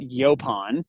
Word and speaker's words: Yaupon: We pronounce it Yaupon.